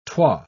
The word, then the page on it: twa: British English